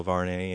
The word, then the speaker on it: Varney: My last name is spelled V as in Victor, A-R-N-E-Y. And if you go to Google and you put in Bill Varney and you put in surf fishing, all of my various pages will come up.